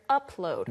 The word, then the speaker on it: upload: Yes, but we're upload. – 네, 그런데 upload. 영어에서는 up, P 발음을 살려줘야 됩니다.